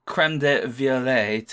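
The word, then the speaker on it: crème de violette: But the creme de violette, to use the correct pronunciation,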